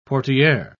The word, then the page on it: portiere: British English